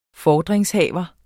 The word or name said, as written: FORDRINGSHAVERE